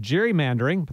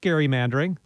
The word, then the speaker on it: gerrymandering: We say it gerrymandering, but the guy it was named after, Elbridge Gerry. I tried to promote gerrymandering, the pronunciation, not the practice, for a few weeks.